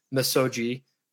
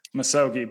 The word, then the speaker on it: misogi: misogi, did I pronounce it right? – Misogi, but close. Misogi. Yeah, okay. – Everyone says misogi. So you're totally off the hook.